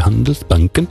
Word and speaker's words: Handelsbanken: Eigenlijk moet ik dat op zijn Zweeds uitspreken. Handelsbanken.